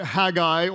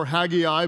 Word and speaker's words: Haggai: Haggai or Haggai, both pronunciations are acceptable.